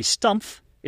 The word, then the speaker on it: Stumpf: Don't forget to pronounce that extra letter F at the end. I hear so often the preparation being referred to as a stump, when in reality, the Stumpf is a German language term meaning blunt or unsharpened.